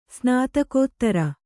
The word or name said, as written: ಸ್ನಾತಕೋತ್ತರ